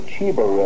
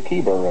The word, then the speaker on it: Chebar: It's not the Chebar River, it's the Kibar River.